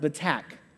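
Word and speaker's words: batach: It's pronounced betak.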